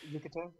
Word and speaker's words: Yucatan: Yucatan, yeah.